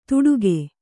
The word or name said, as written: ತುಡುಗೆ